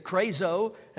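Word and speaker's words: krazo (κράζω): That word for cry, crying, or cried, is krazo, K-R-A-Z-O, accent on the O, and it means this.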